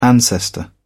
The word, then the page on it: ancestor: British English